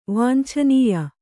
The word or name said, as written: ವಾಂಛನೀಯ